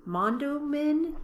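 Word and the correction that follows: Mondawmin: It's Mondowmin.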